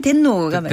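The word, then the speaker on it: 텐노가 (天皇): 근데 이제 일본에서는 텐노, 텐노라 발음이 텐노가 맞아요.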